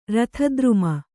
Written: ರಥದ್ರುಮ

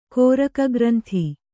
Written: ಕೋರಕಗ್ರಂಥಿ

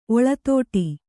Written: ಒಳತೋಟಿ

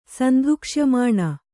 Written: ಸಂಧುಕ್ಷ್ಯಮಾಣ

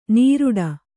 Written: ನಿರೂಟ

